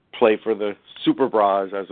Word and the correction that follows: Superbas: it was, or I don't know how you pronounce it. – Superba, I think it was. – Superbas, yeah. And so, right, because they were superb, I guess.